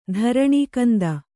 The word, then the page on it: ಧರಣೀಕಂದ: dharaṇī kanda